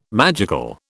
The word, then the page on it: magical: British English